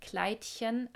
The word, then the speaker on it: Kleidchen: Also die meisten Frauen waren in silbernen Glitzerkleidchen, Kleidchen, also Betonung auf Chen.